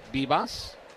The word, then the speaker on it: Vivas: So Bivas, who spells his last name V-I-V-A-S, but he pronounces it Bivas, is now 2 for 2 in this game, and that's 5 hits for the Nationals.